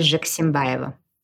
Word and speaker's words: Zhexembayeva: So my first name is Nadya, and my last name is Zhexembayeva.